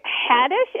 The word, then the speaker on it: Haddish: It's Queen Latifah and Jada Pinkett Smith and this actress named Tiffany, I think Haddish is how you pronounce her last name.